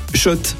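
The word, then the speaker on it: Schott: Oui, Shot et pas Scott, ça fait des années qu'on prononce mal la marque.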